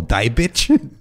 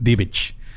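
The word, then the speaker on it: Diebitsch: Que de hecho, como se escribe, se pronunciaría como Dibicht. – Dibicht. Josephine pues, Dibicht. – Ajá, pero es Dibicht.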